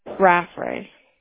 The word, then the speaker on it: Raffray: Your last name, you guys spell it R-A-F-F-R-A-Y, Yes. – How do you pronounce that? Raffray? – Raffray. – Raffray. Okay, got it.